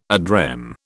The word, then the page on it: ad rem: British English